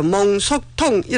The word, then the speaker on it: 몽속통: 몽속통 이렇게 말할 수 있습니다. 발음 가운데서 조금 힘줘서 발음해야 되는 몽속통, 몽골한 몽, 속, 속도란 속.